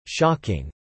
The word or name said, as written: shocking